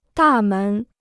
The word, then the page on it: 大门: dà mén